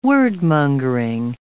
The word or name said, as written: word-mongering